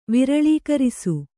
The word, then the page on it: ವಿರಳೀಕರಿಸು: Kannada